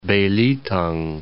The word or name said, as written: Belitung